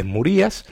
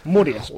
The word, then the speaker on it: Murias: Ah, bueno, si no lleva acento, es Murias. Y si lleva acento en la I, es Murías. – Y si lleva acento en la I, es Murías. Pero Murías. Como no lo lleva, Jesús Murías. – No, como no lo lleva, Jesús bueno, Murias. – Murias, perdón. Exactamente.